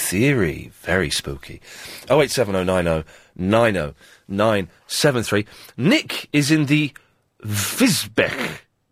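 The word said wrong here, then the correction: Wisbech: That's Wisbeek. – Oh, Wisbeek? – It's pronounced, it's Wisbeek, yes. – Wisbeek? – Yes. – Where the hell is that? – It's not spelt beat, but it's pronounced Wisbeek.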